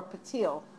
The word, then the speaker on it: פתיל: So, last time when we spoke about Pasil Techeiles, a strand of sky blue, our focus was on the word Pasil or Patil in the Sephardic pronunciation. Well, I suppose the really Sephardic pronunciation would be more like Pasil, but I don't know how to say that.